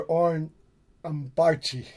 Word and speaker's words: Oren Ambarchi: This is keiji Haino, Jim O'Rourke, and oren ambarchi. Did I pronounce that right, brother? – You did. You got it.